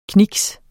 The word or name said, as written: kniks